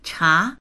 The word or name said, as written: cha2